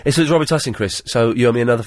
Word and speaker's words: Robitussin: and the correct way to pronounce it is Robitussin.